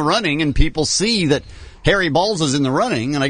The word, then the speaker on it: Baals: It is spelled B-A-A-L-S, but he himself called it was Harry Balls.